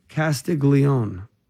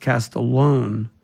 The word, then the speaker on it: Castiglione: Another book, Leap, by Bob Castiglione. His last name is pronounced Castallone, but it looks like Castiglione.